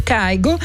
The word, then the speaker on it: Kygo: Prima però la bella parentesi dedicata alle novità di questa settimana: Gracie Abrams, Good Reason, Napoleone, Cucu Marie e Kygo Kigo. Abbiamo scoperto che si può pronunciare in entrambi i modi, perché in norvegese Kigo, in inglese o insomma per tutto il mondo Kygo, insieme a Max McNown, Take Me Back.